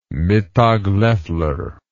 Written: mittag leffler

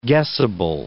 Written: guessable

